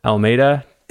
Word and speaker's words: Almeida: I have Sav Almeida.